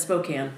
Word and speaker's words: Spokane: Pronounced as Spokane. We checked. – Yes. We asked the local. We asked the pro.